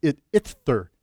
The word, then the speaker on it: itter: It's itter, I think is how it's spelled. It's spelled I-T-T-E-R, and it indicates a physical handicap or malady.